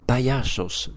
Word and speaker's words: Payasos: Un spectacle qui s'appelle, alors je vais mal le prononcer, donc vous allez me corriger, mais il n'y a pas de souci, qui s'appelle Payasas. – Payasos, qui veut dire paillasse en français, donc les paillasses, c'est aussi des clowns.